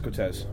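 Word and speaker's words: Coteaz: Yeah, there's no R in there. Cotias. – Yeah, I don't know how to spell it. – I think that is Cotez. I'm pretty sure that's what it is. It's just the Cotez. Without the without the R.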